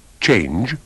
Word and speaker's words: change: Badate bene ai tranelli della pronuncia come la E muta alla fine di change, arrive, take.